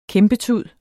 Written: kæmpetud

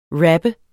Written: rapper

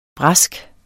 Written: brask

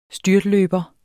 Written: styrtløber

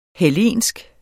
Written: hellensk

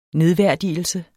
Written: nedværdigelse

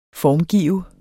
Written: formgive

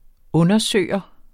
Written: undersøger

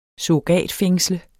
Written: surrogatfængsle

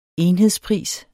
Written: enhedspris

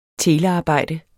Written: telearbejde

